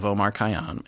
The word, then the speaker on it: Omar Khayyam: Omar Khayyam.